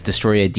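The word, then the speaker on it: Destoroyah: And I'm not pronouncing that wrong, it's Destroya, Destroyah. And it's, a hip-hop for the hip-hop generation.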